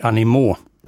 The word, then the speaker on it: Animaux: Steven Isserlis på cello, Dudley Moore spelade piano i Camille Saint-Saëns Le Carnaval des Animaux. Animaux är det korrekta pluralisuttalet på djur på franska, för den som kanske hajade till där lite grann.